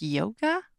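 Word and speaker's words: Jóga: Also spelled, it's J-O-G-A. I think it's pronounced yoga.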